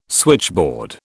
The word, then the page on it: switchboard: British English